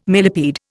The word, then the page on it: millepede: Indian